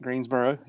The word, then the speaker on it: Greensboro: Am I saying that right? Greensboro? – That's right. Greensboro, yes.